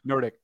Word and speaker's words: Nordic: No, it's just the way you said it. You emphasized the dick part. – The dick? – Yeah. – Nordic.